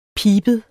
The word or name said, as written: PIBEDE